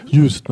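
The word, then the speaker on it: Juist: Das ist ja eigentlich, da warst du, äh, äh, Krimi-Stipendium heißt das Tatort Töverland der Insel Just. Just, ne? spricht man das aus.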